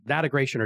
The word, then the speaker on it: Datagration: D-A-T-A... – Datagration or Datagration, yes. – Gration, G-R-A-T-I-O-N. Datagration, okay. It's like data integration, except Datagration or Datagration.